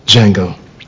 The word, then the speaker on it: Django: Django. Can you spell it? D J A N G O. The D is silent.